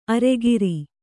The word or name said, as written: ಅರಗುರಿ